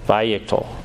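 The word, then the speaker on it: wayyiqtol: So it actually starts with a W, but because of the German influence, it's pronounced with a V, so you would just do a V, vayiktol.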